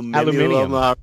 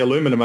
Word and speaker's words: aluminium: Aluminium. – Aluminium, yeah. Yeah, you say aluminum over here, people look at you like you're crazy.